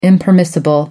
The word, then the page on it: impermissible: American English